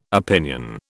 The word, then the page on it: opinion: British English